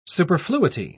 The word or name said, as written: superfluity